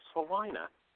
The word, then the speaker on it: Salina: Well, it's, we can tell where I'm from, we can tell where a person is from by the way they pronounce S-A-L-I-N-A. – Selena? – Say that again. – California, S-A-L-I-N-A. – Selena. – Selena? – Actually, here where I'm at, it's Selena.